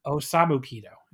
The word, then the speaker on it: Osamu Kido: This is Akitoshi Saito, Kuniaki Kobashi, and the Great Kabuki defeating Akira Nogami, Takayuki Iizuka, and Osomu Kido in 13-12. I totally butchered that. In 13-12. – I commend you for trying. – Osamu Kido is what I should have said in 13-12.